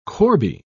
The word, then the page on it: corbie: British English